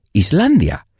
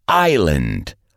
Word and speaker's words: island: La palabra island es muy reconocida, pero la gente suele pronunciar la S. Y si la pronuncias, en realidad dices Iceland, Islandia. Se pronuncia island, island.